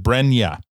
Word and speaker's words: Brenya: Is it Brenya? – Correct.